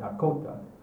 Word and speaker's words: Dakota: Yeah, the proper pronunciation is usually the accent on the second syllable, which means Dakota, or Lakota.